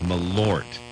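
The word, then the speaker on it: Malört: Yeah, that's how it's pronounced, Malort.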